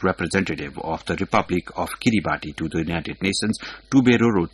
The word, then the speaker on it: Kiribati: Kiribati, pronounced as Kiribati, located in the Central Pacific Ocean, gained independence on 12 July 1979 from Britain.